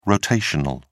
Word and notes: rotational: British English

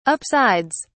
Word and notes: upsides: British English

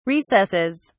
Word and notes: recesses: British English

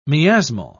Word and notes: miasmal: British English

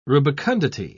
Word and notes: rubicundity: British English

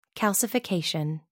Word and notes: calcification: American English

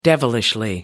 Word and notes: devilishly: American English

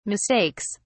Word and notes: mistakes: British English

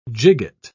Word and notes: gigot: British English

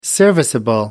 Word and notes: serviceable: American English

